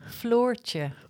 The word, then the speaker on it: Floortje: Floortje. – Dutch. Say it again. – Floortje.